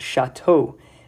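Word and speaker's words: shato (שָׁתוֹ): And it's interesting, when it says that she drank, the Haftorah uses is pronounced shato, that he drank, shato, he drank, referring to basically Elkanah, the husband, was the one that drank, not Chana.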